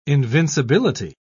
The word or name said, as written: invincibility